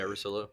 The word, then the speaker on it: Russillo: I don't want to mispronounce his name, but I believe it's Ryan Russillo. – Yeah, Russillo.